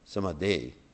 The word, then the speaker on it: samadhi: So the words samadhi, actually not samadhi, samadhi, which is actually the correct pronunciation, means 24 hours a day.